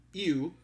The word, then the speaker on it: ewe: An adult female is referred to as a ewe. I can't pronounce it. It's spelled E-W-E.